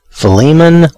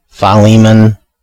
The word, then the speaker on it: Philemon: But it appears from what I'm seeing on different places and from different people that either Philemon or Philemon, you know, kind of a long, the Li is the one, is the syllable that's supposed to be, I think, the most stressed.